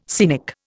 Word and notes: scenic: Indian

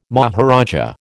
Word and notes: maharaja: American English

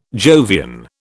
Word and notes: Jovian: British English

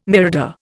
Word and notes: mirador: Indian

